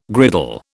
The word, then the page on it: griddle: British English